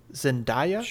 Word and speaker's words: Zendaya: We have, I can never say her name correctly, Zendaya. Is that it? Zendaya? – Sure. Sure.